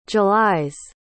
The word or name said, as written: july's